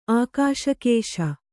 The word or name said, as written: ಆಕಾಶಕೇಶ